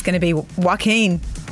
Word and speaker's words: Joaquin: which is going to star Joaquin Phoenix.